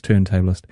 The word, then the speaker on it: turntablist: Or turntablist, as it looks to be pronounced, but it's not. It's turntablist.